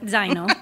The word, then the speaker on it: zaino: Do you use the Italian pronunciation or does it call it something else in English? Zaino? – Zaino. It's very similar Zaino. In Italian.